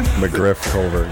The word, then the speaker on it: McGriff-Culver: McGriff-Culver.